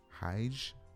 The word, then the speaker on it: hygge: What is, how is this word pronounced, y'all? H Y G G E. Uh, Hajj. I've not been able to pronounce this word. so we're gonna find out specifically. Hmm. It says it sounds a lot like the English word hug,